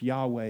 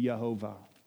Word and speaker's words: Yahweh: I know that the Lord, she uses the intimate name of God, YHWH, Yahweh is the best pronunciation that we have, maybe Yehovah, the existing one.